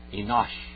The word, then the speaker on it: Enosh: Enosh. Same just like it is in English. Enosh. Okay? Az. Az. A-Z. Huchal. H-U-C-H-A-L. Likro. L-I-K-R-O.